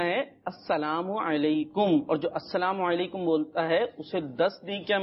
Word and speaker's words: السلام علیکم: ہمیں درست تلفظ کے ساتھ بولنا ہے۔ بعض بچے کیا کرتے ہیں، کوئی بول رہا ہوتا ہے سلام علیکم،